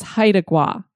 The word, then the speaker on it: Haida Gwaii: The way that I was saying it, Haida, it actually is Haida. So I believe it's Haida Gwaii.